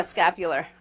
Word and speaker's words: scapular: I always pronounce it wrong. Scapula? Is that what you say? – Scapular. Uh-huh.